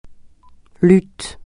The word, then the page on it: lutte: francais